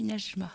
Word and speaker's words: Najma: de l'agenda Najma, c'est comme ça qu'on prononce Oui, Najma.